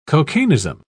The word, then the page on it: cocainism: British English